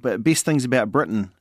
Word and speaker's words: Britain: Another one says Britain rhymes with kitten.